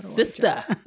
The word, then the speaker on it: SISTAHFRIENDS: Because it's Sista. SISTAHFRIENDS. No, I wasn't mispronouncing it. I was saying Sista. It wasn't just my colloquial slang. I was saying it. That's how it's spelled.